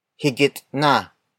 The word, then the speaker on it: higit na: If you prefer a native Tagalog version of mas, you can say higit na, higit na, higit na. Higit na is spelled as H-I-G-I-T space N-A.